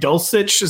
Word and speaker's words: Dulcich: getting Greg Dulcich. Is that how you say his name, Kerrane? – That is how you say it.